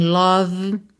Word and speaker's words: love: Cuidado para não dizer love, nem love, tá? São duas questões aqui que a gente tem que prestar atenção. Nem o O tem som de O, é a, e o E final não é pronunciado. Então, love.